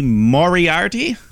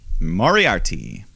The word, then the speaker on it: Moriarty: But in the meantime, Bobby Fish takes on Lee Moriarty. Did I say it right? – You said it right. – Moriarty.